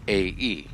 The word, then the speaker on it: Thermopylae: And the interesting thing, and this is about the Battle of Thermopylae, Thermopylae. Now, everyone, like I did, wondered how you spell that after someone pronounces Thermopylae. It's not exactly, doesn't exactly look how you think it would be spelled from hearing someone pronounce it.